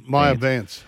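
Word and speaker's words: Maija Vance: And is it Maya Vance, or how do you say Major Vance? M-A-I-J-A. – Maya Vance. – Maya, yeah, the Maya.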